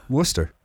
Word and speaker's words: Worcester: Is it Worcester? – Worcester. – Worcester. All right. – That's how you say it. And the same way you say Worcestershire. It's Worcestershire sauce.